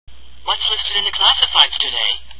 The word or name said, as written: listed